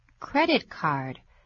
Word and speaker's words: credit card: Credit card. Credit is spelled C-R-E-D-I-T. Card is spelled C-A-R-D, credit card. 请注意它的重音是在第一个音节 credit card. – 不要念成 credit card, 啊，记住。